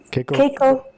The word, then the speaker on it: Keiko: And then I always say to people, it's Keiko like J-Lo. – I like it. I like that. – Because, you know, it's spelled out K-E-I-K-O. So a lot of people pronounce it Keiko. – Yeah. Keiko like J-Lo. – Yes. Keiko with a little eh, right. Like J-Lo.